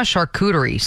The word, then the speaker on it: charcuterie: I'm a charcuterie snob is what I am. – A charcuterie snob. You even say it properly.